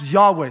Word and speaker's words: Yahweh: It's pronounced Yahweh. Say Yahweh. Yahweh. That's a cool name. That's the Hebrew articulation of that.